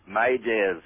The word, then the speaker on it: m'aidez: Well, it comes from the French word Maidez, M-A-I-D-E-Z, meaning help me, which is pronounced by the British as Mayday.